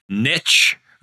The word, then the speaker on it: niche: It niche. Just rhymes with fish, man.